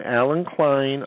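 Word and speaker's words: Allen Klein: And then my name, Alan Klein, all one word, .com.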